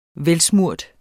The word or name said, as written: velsmurt